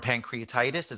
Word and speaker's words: pancreatitis: Almost died from pancreatitis.